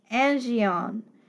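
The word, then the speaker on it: angion (ἀγγεῖον): In the Greek, vessels is pronounced angion, which means a pail or a receptacle.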